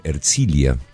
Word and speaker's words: Herzliya: En este momento se encuentra en Herzliya, en las afueras de Tel Aviv. Bienvenido, Leo, y decime si pronuncié bien el nombre del lugar. – Bastante bien. – Bastante bien. – pero bastante bien.